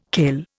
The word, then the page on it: kail: Indian